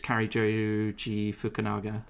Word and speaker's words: Cary Joji Fukunaga: I think it's Cary Joji Fukunaga. I probably completely butchered his name. – no, no, spot on.